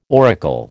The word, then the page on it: auricle: American English